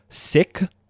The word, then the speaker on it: Sikh: And just so that everybody knows, Sikh, S-I-K-H, right? You'll hear the pronunciation Sikh. You'll hear, you know, different variations in culture.